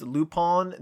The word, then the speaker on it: Lupin: No, it's if you're unfamiliar, it's the word Lupin, pronounced Lupin.